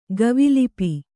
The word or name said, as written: ಗವಿಲಿಪಿ